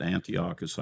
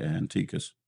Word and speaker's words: Antiochus: And some people pronounce it Antiochus, I say Antiochus.